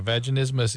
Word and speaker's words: vaginismus: I looked it up online and found something called, I don't know how you say it, vaginismus or vaginismus. – Yeah, vaginismus is a spasm of the floor of the pelvic musculature.